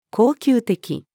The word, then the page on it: 恒久的: female